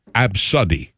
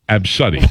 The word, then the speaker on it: ABCDE: A, B, C, D, E, pronounced Absuddy. Absuddy.